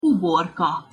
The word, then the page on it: uborka: Hungarian